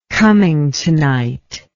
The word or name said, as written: cummingtonite